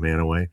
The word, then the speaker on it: Mantua: It's not a household name, but many years ago, I lived in a small town in Ohio called Manaway, M-A-N-T-U-A. Most people pronounce it Mantua. That's how we know you're not from Manaway.